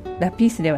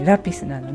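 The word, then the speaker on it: lápiz: それから、先週出てきたラピス、これもラピースではなくてラピスなので A の上にアクセントをつけます。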